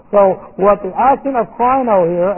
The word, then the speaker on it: krino: This is an aorist participle of the word krino. Here's our word. K-R-I-N long O.